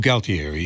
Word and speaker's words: Galtieri: G-A-L-T. – Oh, Galtieri, yeah.